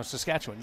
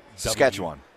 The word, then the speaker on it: Saskatchewan: No, Saskatchewan. Like, you don't put the wan. It's not wan. Saskatchewan. Like, almost. – Saskatchewan.